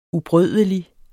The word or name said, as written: ubrødelig